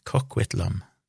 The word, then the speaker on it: Coquitlam: lyktes han ikke med, men endte det opp på Riverview Hospital i en by eller et sted som heter Coquitlam. Coquitlam. Hæ? Det koster noe å uttale det. – Ja, ok. – COQ begynner det med, så Coquitlam.